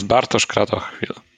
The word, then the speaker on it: Bartosz Kratochwil: So this is Bartosz Kratochwil.